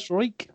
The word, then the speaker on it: Rozenstruik: I'm going to say Rozenstruik, which is definitely not right. – Struik, yeah.